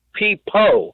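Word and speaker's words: Peapo: It's Peepo with a P-O at the end.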